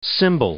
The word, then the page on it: symbol: noun